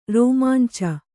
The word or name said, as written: ರೋಮಾಂಚ